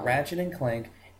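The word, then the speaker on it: Ratchet & Clank: Okay, you'll be kicked off the podcast if you cannot say it correctly. – Ratchet Clank it. Leave me alone. – Ratchet and Clank, and this is a nerd podcast. If you do not say it right, we will kill you.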